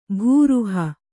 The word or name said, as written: ಭೂರುಹ